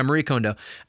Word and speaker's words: Kondo: Is it Kondo? Sorry, I've been tidying up, people. I have. I want to make sure I'm saying her name right. I'm looking at my Audible right now. Yeah, Marie Kondo.